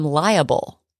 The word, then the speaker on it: liable: You have to say I'm liable to do that because it comes from the word liability. Liability. So you say liable.